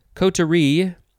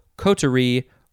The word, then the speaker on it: coterie: Coterie, or, let's see, you can emphasize the first syllable, coterie, or the last syllable, coterie. C-O-T-E-R-I-E.